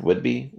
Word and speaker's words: Whidbey: is it Whidbey Island? Whidbey? – Correct.